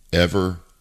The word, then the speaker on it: Evergrande: E-V-E-R-G-R-A-N-D-E, Evergrande. Grand with an E at the end, Evergrande, I believe is how you say it.